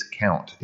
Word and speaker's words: Comte: It's not Comte and it's not Kant. It's Count. It's pronounced like Count.